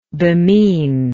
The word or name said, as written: bemean